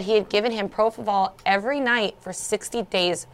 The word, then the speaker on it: propofol: He was only on one drug that they had determined from his autopsy lab reports, and it was the propofol. – How do you say it? – Yeah. – Propofol.